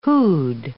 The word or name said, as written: who'd